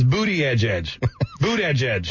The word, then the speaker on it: Buttigieg: How come nobody's told me it's not Buddy Geek? – Buddy Geek is the way it's spelled, correct. – Okay. – But it is actually Boot Edge Edge, if you can believe that. Buttigieg.